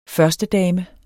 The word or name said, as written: førstedame